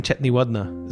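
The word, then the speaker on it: Chetiyawardana: Chathi Wardhana. Yeah, the T-I and the D soften.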